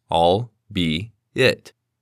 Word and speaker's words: albeit: The word has three syllables and sounds like all, be, it. However, when spoken quickly, the L sound can almost sound silent. Additionally, it does not have a hard T sound at the end of the word.